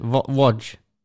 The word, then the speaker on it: Łódź: Łódź? – Yes.